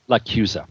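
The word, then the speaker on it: LaChiusa: Michael John Lachusa's is that the official pronunciation, Lachusa? – Lachusa. – Lachusa. I always get that wrong.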